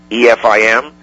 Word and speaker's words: EFIM: The acronym I've come up with for these shows is pronounced EFIM. EFIM, Entertainment for the Ignorant Masses.